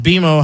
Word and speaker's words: BMO: It's pronounced BMO. Hello. – Right. – Oh, BMO, my bad.